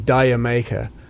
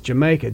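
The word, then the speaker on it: D'yer Mak'er: I remember many DJs saying Diamaker. Page used to get a big laugh. The whole band used to get a tremendous laugh out of hearing the American DJs trying to pronounce something which is a play on words. It's Jamaica, the Jamaica. That's what it means.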